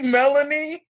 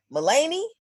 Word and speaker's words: Melanie: Like literally Melanie? – Maloney.